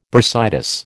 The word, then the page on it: bursitis: American English